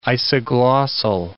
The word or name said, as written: isoglossal